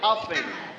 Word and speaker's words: often: Often. – Often. – Often. So T, it's a silent sound. We don't pronounce T, okay? We say often.